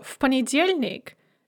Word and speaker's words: в понедельник: So, в понедельник. Note that because P is voiceless, we devoice V as well, and it's pronounced F. В понедельник, on Monday.